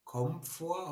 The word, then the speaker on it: Komfort: Wie, wie spricht man das eigentlich aus? Komfort oder Kon. Wie heißt denn das eigentlich? Kom Komfortzone. Komfort! – Ja!